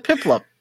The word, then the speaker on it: Piplup: I thought I said Piplup. – Daniel, what did he say? Were you listening? – I wasn't paying attention. I thought he said it right. – Pupplup. – No. Pupplup? Oh, you might have. I said Pupplup? – You did. – Okay. Piplup. Pupplup.